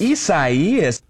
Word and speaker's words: Isaias: Just in case we know you're going out of town and, you are asked to, maybe you're asked to pronounce the name of that hurricane. – Yes. – Isaias.